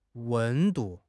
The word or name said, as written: wen1 du4